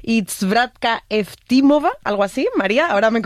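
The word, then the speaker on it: Zdravka Eftimova (Здравка Ефтимова): Ayúdanos a pronunciar los autores. – A ver, Georgi Gospodinov, sí. Zdravka Eftimova y Vera Mutavcheva.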